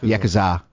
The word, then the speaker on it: Yakuza: Yakuza.